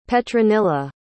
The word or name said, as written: petronilla